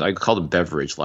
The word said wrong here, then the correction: Beverett: last week, but it's Beverett.